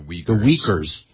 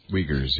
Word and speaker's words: Uyghurs: It's U-I-G-H-U. – The Uyghurs. – Uyghurs, yeah.